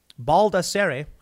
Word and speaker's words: Baldassare: Both Carrick and Mark Baldassar, Baldassare, I pronounce it,